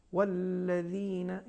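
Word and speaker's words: والذين: اور دوسرا نقطہ حرف ذال کے بارے میں ہے، یعنی دال زال جسے کہتے ہیں، یہ زال ترقیق کے ساتھ ادا ہونا چاہیے، جیسے الذین یہ تفخیم ہو جائے گی، لہٰذا کوشش کریے حرف زال کو ترقیق کے ساتھ تلفظ کریے، والذین یہ صحیح تلفظ ہے اس کا.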